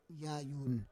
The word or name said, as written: yayun